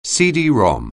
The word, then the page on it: CD-ROM: anglais